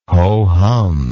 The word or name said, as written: ho hum